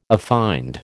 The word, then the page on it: affined: American English